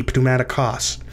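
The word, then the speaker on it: pneumatikos (πνευματικός): No, Buffalo Bev, in English the P is silent, but not in Greek. In Greek you pronounce the P, pneumatikos.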